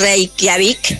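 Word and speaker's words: Reykjavík: Hola, es Reykjavik.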